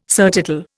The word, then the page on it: surtitle: Indian